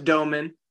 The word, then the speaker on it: Domann: No, it's Domann.